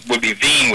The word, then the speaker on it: Wien: And it's actually, Wien would be the German pronunciation, because W is pronounced like a V, but close enough.